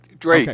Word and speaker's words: Drache: Drache.